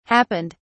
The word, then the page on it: happened: British English